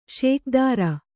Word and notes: ಷೋಕೀದಾರ: ṣōkīdāra

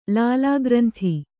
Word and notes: ಲಾಲಾಗ್ರಂಥಿ: Kannada

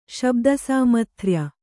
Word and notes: ಶಬ್ದಸಾಮಥ್ರ್ಯ: Kannada

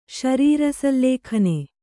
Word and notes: ಶರೀರಸಲ್ಲೇಖನೆ: śarīra sallēkhane

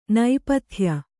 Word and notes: ನೇಪಥ್ಯ: nēpathya

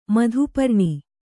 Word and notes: ಮಧುಪರ್ಣಿ: madhu parṇi; Kannada

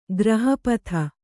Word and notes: ಗ್ರಹಪಥ: graha patha

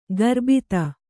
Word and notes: ಗರ್ಬಿತ: Kannada

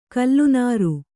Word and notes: ಕಲ್ಲನಾರು: kallanāru

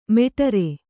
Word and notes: ಮೇಢ್ರ: Kannada

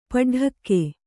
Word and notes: ಪಡಡಕ್ಕೆ: paḍaḍakke

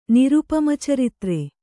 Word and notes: ನಿರುಪಮಚರಿತ್ರ: nirupama caritra

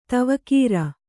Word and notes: ದೇವಗುರು: dēva guru

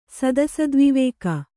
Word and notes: ಸದಸದ್ವಿವೇಕ: Kannada

